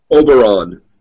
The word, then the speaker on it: Oberyn: Oberyn. Oberyn. Almost like Obi Wan, but with an R in there.